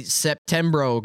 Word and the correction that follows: Septiembre: because I don't know how to say it in Spanish anymore. – Septiembre. – Septiembre is correct.